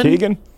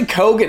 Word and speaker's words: Keoghan: Barry Keoghan? – Keegan? – It's actually Kogan. It's way easier than we all made it hard.